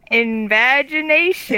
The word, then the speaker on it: invagination: Invagination. – It rhymes with imagination, everybody.